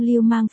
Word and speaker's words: Lưu Mang: LT, trong tiếng Trung Lưu Mang phát âm giống Lưu Manh.